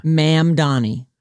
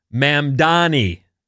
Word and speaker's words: Mamdani: Zohran Mamdani was elected mayor of New York City, and that's how you say his name. – Mamdani. – Zohran. – Yeah. – Mamdani.